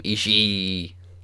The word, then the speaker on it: Ishii: I think also one of my new favorite things is JR pronouncing both I's at the end of Ishii's name. Ishii. – Mm-hmm. Well, I mean, that's, you're supposed to say Ishii, but I love, yeah, no, it's like when he says it, he adds like 6 E's on the end of it.